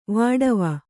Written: ವಾಢಾವಾ

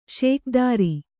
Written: ಷೋಕೀದಾರ

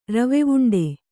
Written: ರವೆವುಂಡೆ

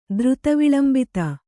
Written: ದ್ರುತವಿಳಂಬಿತ

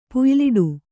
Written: ಪುಯ್ಯಲಿಡು

